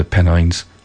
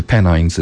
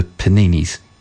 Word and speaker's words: Pennines: And when it came to say it, they pronounced the Pennines as the Peninis.